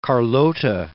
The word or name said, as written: Carlota